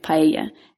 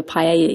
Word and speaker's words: paella: Now, a lot of people, when they think of Spain, they think of paella. And paella, which is a delicious rice dish. – Paella, my paella. Parents would say. – Yeah. That double L is supposed to be pronounced like a Y, but, you know, whatever.